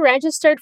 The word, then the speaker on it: registered: Have you registered Be careful with the stress syllable here. Say it again.